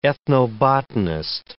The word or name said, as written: ethnobotanist